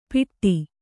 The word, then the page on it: ಪಿಟ್ಟಿ: piṭṭi